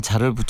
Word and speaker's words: 잘: 이게 원칙적으로 단음이에요. 잘. 네. 이렇게 짧아야 돼요. 이거 길게 발음하면 죄다 반대가 되겠죠.